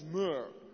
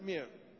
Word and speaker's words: mirre: Dat is mur, niet meer. Dus ik heb het echt nagekeken. Nog mur. Helaas hoor, ik had liever meer gehad, maar het is mur.